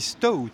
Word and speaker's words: Stout: Je l'ai bien prononcé, ça se dit Stout, pas non. – Stout.